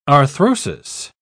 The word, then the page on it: arthrosis: British English